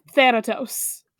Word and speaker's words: Thanatos: It's, okay, it's AE as in bad, so... Thanatos.